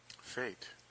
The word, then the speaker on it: Phate: And her name was P-H-A-T-E. – Fate.